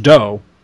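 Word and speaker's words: Dough: His name is Doe, at least I'm assuming it's pronounced Doe. It's D-O-U-G-H, Doe Hubler.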